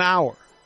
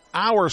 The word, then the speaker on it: Auer: Auer spelled A-U-E-R, pronounced hour as in we are just past the 4 o'clock hour.